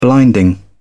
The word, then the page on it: blinding: British English